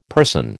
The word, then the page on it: person: American English